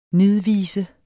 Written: NIDVISENS

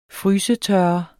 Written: frysetørre

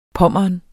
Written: Pommern

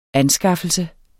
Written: ANSKAFFELSE

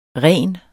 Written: rens

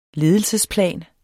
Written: ledelsesplan

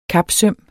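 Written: kapsøm

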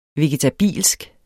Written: vegetabilsk

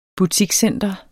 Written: butikscenter

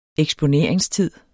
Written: eksponeringstid

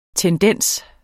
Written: tendens

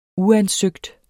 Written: uansøgt

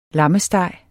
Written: lammesteg

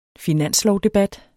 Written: finanslovdebat

